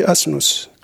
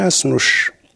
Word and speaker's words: asnous: Le petit de l'âne en berbère, jusqu'à présent, ça se dit asnous. Asnous, et prononcer le S, ça devient asnouche.